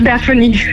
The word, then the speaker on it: Daphne: Spreek je dat juist uit, Daphne? – Ja, het is Daphne.